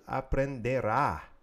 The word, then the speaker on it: aprenderá: And that's él aprenderá. Accent on the last A in the future tense.